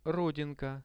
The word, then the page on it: rodinka: Russian